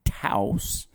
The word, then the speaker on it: Taos: Angie and I took an end of spring, beginning of summer vacation to northern New Mexico, Santa Fe and Taos, is how you say that, which was outstanding.